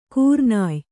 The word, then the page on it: ಕೂರ್ನಾಯ್: kūrnāy